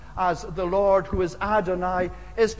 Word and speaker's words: Adonai: It's the word Adonai, or Adonai, as some would pronounce it.